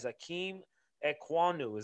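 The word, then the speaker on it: Ikem Ekwonu: Akeem Ekwonu, is that correct? – Oh, Quanwu? – Yes, Okay.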